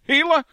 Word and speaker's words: Gila: You know, Pat, I believe you pronounce it with the H sound. – Gila? Gila. I mean, it says a G, but it doesn't have a G.